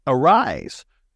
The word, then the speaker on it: Aeriz: And joining me right now to go talk about it is the marketing director at Arise. And that is spelled A-E-R-I-Z.